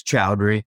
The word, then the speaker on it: Choudhury: It's Chowdhury.